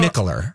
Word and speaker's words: Mikkeller: So Mikkeller.